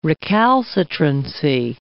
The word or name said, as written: recalcitrancy